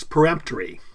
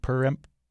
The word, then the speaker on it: peremptory: Because we're not trying to preempt something, right? We're trying to perempt something. – Exactly. – P-E-R-E-M-P-T, peremptory.